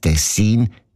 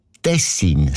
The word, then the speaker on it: Tessin: Aber der SRF-1-Hörerin Regula Stern-Grieser von Locarno ist aufgefallen, dass gewisse Leute statt Tessin Tessin sagen. Also Betonung auf mich mit einem kurzen I.